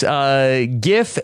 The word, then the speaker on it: GIF: It says on the label itself that the JIF creamy peanut butter is with a soft G. And then there is the GIF peanut butter with a hard G.